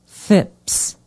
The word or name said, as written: phipps